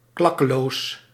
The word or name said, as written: gratuit